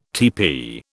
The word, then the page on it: teepee: British English